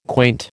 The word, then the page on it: quaint: American English